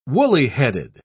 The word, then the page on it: woolly-headed: British English